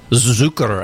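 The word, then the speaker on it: Zücker: And we started off the set with Save Room for Me by the Fastbacks off of an album called Zucker, I believe it is pronounced. I always get confused when the phonetic symbols are put on top of vowels, but I think that's with the umlauts, how you say Zucker.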